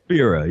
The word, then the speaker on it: Spira: Spira.